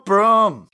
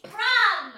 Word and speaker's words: Brum: Broom, broom, broom. – It's Brum. – Oh, okay. – Brum. – It's Brum, not Broom.